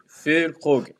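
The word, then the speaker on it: Füllkrug: Et puis si comme, si comme Alban, vous galérez avec la prononciation de, de Fulkrog, écoutez un petit peu les, euh, les matchs commentés par euh, Jean-Charles Sabatier, c'est Fulkrog.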